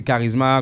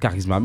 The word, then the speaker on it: Karizma: Le groupe, c'est Charisma, comme ça se prononce en créole. Charisma, K-A-R-I-Z-M-A. Donc, Charisma. Merci, James Clément.